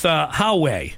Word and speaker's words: Huawei: On par with Huawei.